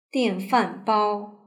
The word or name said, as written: dianfanbao